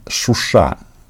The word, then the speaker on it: Шуша: А, пока м, Путин борется с а, проклятым Западом, м, Эрдоган отправ, отправился в город Шуша. А, по-моему, вот здесь я сейчас правильно – а, поставил ударение, что вы мне делали замечание.